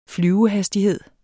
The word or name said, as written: flyvehastighed